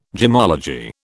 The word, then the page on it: gemmology: British English